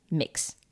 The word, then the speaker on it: Mx: For this, we have the suggestion MX, pronounced mix.